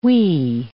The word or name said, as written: whee